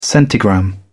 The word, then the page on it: centigram: British English